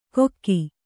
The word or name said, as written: ಕೊಕ್ಕಿ